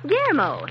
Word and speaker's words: Guillermo: Say, Miss Martha, who's this guy from South America, Guerrillamo? – Oh, foghorn. That's pronounced Guillermo. Oh, that's south of the border for William or Bill.